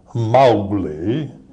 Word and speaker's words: Mowgli: I said, how do you pronounce Mowgli? And this wonderful old voice came out of there, like Edna May Oliver, and she said, you pronounce it Mowgli.